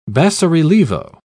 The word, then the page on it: basso-relievo: British English